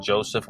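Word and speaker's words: Josephe: And if you go to my actual Facebook page and friend me, is Joseph Pop Buchanan. So my name is spelled Joseph with an E at the end. That's J-O-S-E-P-H-E.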